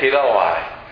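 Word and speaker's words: chilioi: It's C-H-I-L-I-O-I. And the C sounds like a K. Kiloi.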